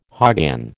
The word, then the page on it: hoggin: American English